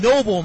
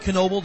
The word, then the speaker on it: Noble: It is Noble, not Knoble. The K is silent on that one.